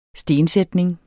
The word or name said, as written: stensætning